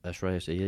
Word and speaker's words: Shreyas Iyer: Shreyas Iyer, am I saying that right? – Shreyas Iyer, yes.